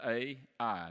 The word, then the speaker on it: Ai: Just two letters, A-I. And that's how it's pronounced, Ai.